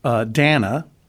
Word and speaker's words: Dana: This concoction was suggested to us by listener Dana, pronounced like banana, it says here.